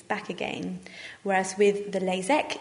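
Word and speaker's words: LASEK: First of all is the LASIK, which is spelt with an IK, and the other one sounds exactly the same, but it's spelt with an EK, LASIK.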